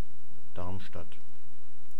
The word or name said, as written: Darmstadt